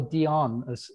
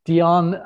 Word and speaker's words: Diane: But I think, you know, Diane for me, or Dion, as she told me, is the correct pronunciation. Dion, because of the Studio 54 association, I do really like that whole aspect.